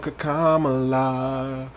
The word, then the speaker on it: Kamala: Kama, kama, kama, kama, kama, kamala.